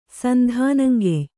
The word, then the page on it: ಸಂಧಾನಂಗೆಯ್: sandhānangey